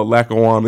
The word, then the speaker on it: Lackawanna: That right there had to be a challenge to be kind of isolated in Lackawacka, Pennsylvania. Did I say that right? – Oh, Lackawanna.